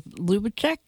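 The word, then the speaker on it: Lubizec: And I hope people will seek out your novel, In the Shadow of Dora, as well as The Commandant of Lubecek. Is that how you pronounce it? – That's correct. Yes.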